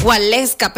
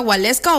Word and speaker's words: Waleska: Waleska, pero se pronuncia así como lo estoy diciendo yo. O Waleska. O sea, porque se escribe con W, pero se pronuncia Waleska o Waleska.